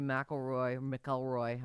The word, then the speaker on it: McIlroy: Rory McIlroy, how do you say his last name? – McIlroy. You got it.